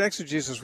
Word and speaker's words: exegesis: I heard you talking or explaining about, I don't know if I'm pronouncing it right, exegesis? – Exegesis, yeah, uh-huh.